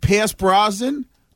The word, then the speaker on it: Brosnan: Yo, British TV, they got a new show coming out, Mobland, with Tom Hardy and Pierce Brosnan.